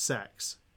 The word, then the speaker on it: CeX: Did you know the shop CEX is actually pronounced sex? – No. – It was in one of their ads. They announced it saying, come down to sex.